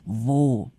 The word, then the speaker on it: wo: Wo 一样，它又是一个用 W 这个英文字母 V 开头的疑问词，所以也请大家注意发音，你要咬住你的下唇，vo vo。好，vo is the post here，这里的邮局在哪儿？vo is the post here。那如果你是要去换钱，或者你要存钱，你要开户，你要找银行咯，vo is the bank。